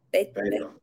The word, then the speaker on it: Beto: Beto or Beto.